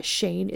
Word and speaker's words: Cheyne: Shane is spelled C-H-E-Y-N-E. – Okay. – I'm just throwing that out there. I did not know how to pronounce it for a long time.